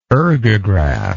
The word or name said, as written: ergographic